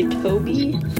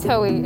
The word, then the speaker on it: Toei: You say Toby? – Toei. It's spelled T-O-E-I.